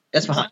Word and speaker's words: Isfahan: Is it Isfahan? Is that how you pronounce? – Isfahan, yeah, that's right. Isfahan.